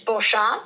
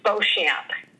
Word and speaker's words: Beauchamp: How you pronounce it is in French, it's Beauchamp, but in the lovely land of the United States, it's Beauchamp.